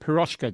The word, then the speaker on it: Piroshka: And, of course, Miki, you've already mentioned Blush and your current band, Piroshka.